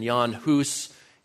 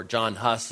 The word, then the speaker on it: Jan Hus: and Jan Hus in Bohemia, or John Hus as his name is sometimes pronounced.